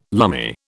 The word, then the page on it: lumme: British English